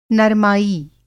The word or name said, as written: नरमाई